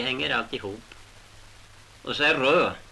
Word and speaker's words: Rud: Hur skrivs det? – R-U-D. – Och de uttalar det? – Rö, men det gör vi inte nu. Det var när jag var liten som de sa Rö. Men nu säger de alltid Rud.